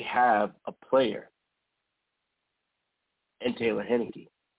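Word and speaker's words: Heinicke: Because there's nowhere, and I believe I called the young man, Tyler Henke. I called him everything but his name, Taylor Henneke.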